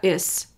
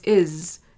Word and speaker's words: is: So if we're talking about the word is, so if you're saying the word is, that S sound at the end of the word doesn't say, it's not is, it's is. It's that Z sound.